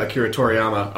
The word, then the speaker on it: Akira Toriyama: But she was designed by Akira Toriyama. I'm going to butcher that name. I actually have no idea. – You had it. You got it. You did it. – Akira Toriyama designed it.